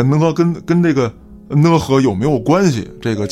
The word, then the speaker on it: 讷河: 当年呢，有这么一句话啊，叫不想活，去讷河。这讷河呢是这个东北的这么一个地名儿，这个字儿我平时一直读成讷，啊，但是说这个有东北的朋友指指正我啊，说你必须得读讷。